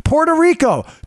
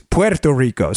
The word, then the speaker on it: Puerto Rico: Or someone corrected me. Puerto Rico. I'm sorry. That's the sometimes it just go. But they are correct. If you're going to pronounce Oregon right, and Nevada. It's Puerto Rico.